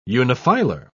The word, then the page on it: unifilar: British English